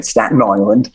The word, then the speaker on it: Staten Island: Staten Island.